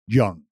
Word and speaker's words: Jung: Josh Jung.